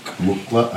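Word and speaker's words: muqw'lha: Right here. M-U-Q-W apostrophe L-H-A. Mukla.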